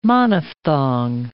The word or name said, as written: monophthong